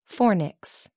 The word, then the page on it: fornix: American English